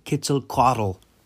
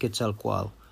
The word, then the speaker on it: Quetzalcoatl: Or is it possibly in reference to like the Mesoamerican deities aligned with Quetzalcoatl, or as white people say, Quetzalcoatl? It's in Nahua, it's pronounced Quetzalcoatl, to my knowledge.